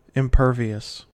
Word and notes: impervious: American English

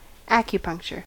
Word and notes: acupuncture: American English

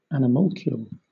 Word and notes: animalcule: British English

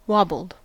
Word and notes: wobbled: American English

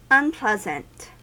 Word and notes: unpleasant: American English

